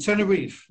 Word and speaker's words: Tenerife: The Tenerife, Well, the island, Tenerife. – Tenerife, yeah. – Tenerife, but then, you know, if you're Portuguese or Spanish, you say Tenerife.